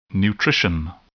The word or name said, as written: nutrition